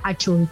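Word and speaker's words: Hachuy: Achul. Achul se escribe H-A-C-H-U-Y y Leila va con Y.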